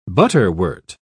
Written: butterwort

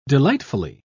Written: delightfully

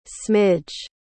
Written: smidge

